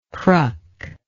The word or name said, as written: cruck